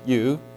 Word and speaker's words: eu: And so he took the Greek prefix EU, pronounced you, like in eulogy or Eucharist, little prefix which simply means good or glad, and he added that prefix to the word catastrophe, creating the word eucatastrophe.